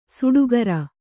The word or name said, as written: ಸುಟಿಕರ